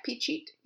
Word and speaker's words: Phichit: Phichit. Am I saying that right? – Yep, Phichit. Yep.